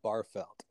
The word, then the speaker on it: Bargfeldt: I pronounce it John Bargfeldt. So with a G that's silent.